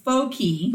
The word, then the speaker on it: Fuck: His name is Guy, and then F-U-C-K. – Guy Fuck. – Guy Fuck, but even though in Brazil it's pronounced Foki.